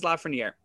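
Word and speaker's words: Lafrenière: I've heard Lafreniere. I pronounce it Alexis Lafreniere, and I think that that's how it should be pronounced.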